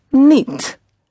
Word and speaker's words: niet: The word for not is niet. N-I-E-T. The I-E sound always pronounced as E. It's a very neat word.